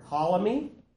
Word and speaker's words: Ptolemy: Two generals in particular of notoriety are Ptolemy, okay, the P is silent, and Seleucus.